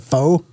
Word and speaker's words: pho: Pho? – Yeah. – Pho. – Yeah. P-H-O.